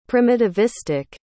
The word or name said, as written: primitivistic